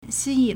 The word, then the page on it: 吸引: xīyǐn